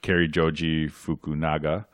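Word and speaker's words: Cary Joji Fukunaga: Yeah, I mean, I think that's a lot of credit given to Kari Joji Fukunaga. Hope I said his name right. – Nailed it.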